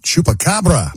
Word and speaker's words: Chupacabra: And Sunday, Chupacabra.